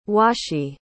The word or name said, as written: washi